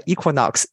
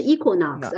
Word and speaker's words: Equinox: 哎，Frank，我觉得你看我其实我的发音是错误的。我听了你之后，其实应该是 Equinox，而不是 Equinox。